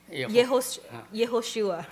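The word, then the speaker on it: Yehoshua (יהושע): The Hebrew pronunciation of the word Jesus is Yehoshua.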